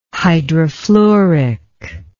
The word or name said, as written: hydrofluoric